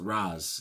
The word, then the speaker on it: Ra's: And they were like, cool, we'll pronounce it Raz.